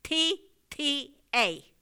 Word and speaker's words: Caltanissetta: CALTANISSETTA.